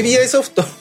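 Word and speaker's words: Ubisoft: まあやってる、やってないわけじゃないんですけれども、うんうん、まあ、今回の、はいはいはい、まあ、テーマが UBI ソフトですね。 – UBI ソフトな。 – UBI ソフト。そうそうそう。 – 伝わんねえからってなっちゃう。 UBI。 – UBI ソフト。はいはい、まあ英語で言うと UBI っていうなぜかみんな言うんですけどね。 UBI って書いてね。 – ネイティブ発音。 – UBI ソフト。そう、ネイティブ発音。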